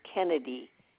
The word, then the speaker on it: Kennedy: J-A-N-E-T. That's Mrs. Janet. Middle initial S, like as in Susan. Last name is Kennedy. K-E-N-N-E-D-Y.